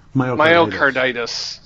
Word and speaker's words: Myocarditis: Myocarditis. – Myocarditis.